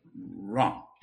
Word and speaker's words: wrong: Wrong. With a capital R. – Which is wrong. – Yeah, that's wrong. Yeah. Unless you pronounce it differently. Can you say the word wrong without the sound W?